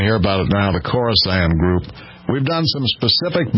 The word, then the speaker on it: Khorasan: K-H-O-R-A-S-A-N, Khorasan.